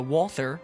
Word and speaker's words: Walther: Uh, Caleb underscore Crawdad is mine, and uh, and mine is uh, off the Walther.